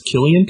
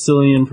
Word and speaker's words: Cillian: Did you know that Cillian Murphy, his name is pronounced Killian? – Killian, yeah.